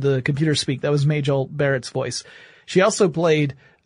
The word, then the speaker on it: Majel: And the project name at Google was, uh, uh, Majel or Magel, depending upon how you want to pronounce it, but Majel would be the, the way her name was actually pronounced.